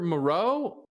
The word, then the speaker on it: Moreau: So it's Foster Moreau. Is that how you say it? – Yeah, you got there it.